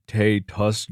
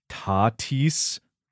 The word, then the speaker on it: Tatis: Also, you mispronounced one of the biggest star's names in an episode, calling him Fernando Tatis Jr. when it's pronounced Tatis. – Tatis.